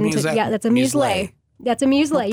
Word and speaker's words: muselet: Now, it wasn't until 1844 that the wire cage that fits over the cork, which is called a muselet. – Sorry, muselet? – Muselet. – Can you spell that for us? – M-U-S-E-L-E-T. It's French.